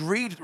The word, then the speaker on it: Reid: I've just realised Reid rhymes with speed.